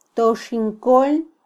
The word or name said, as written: toxinkol